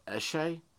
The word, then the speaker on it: eshay: Pronounced essay. Like essay, but was sounds so eshay.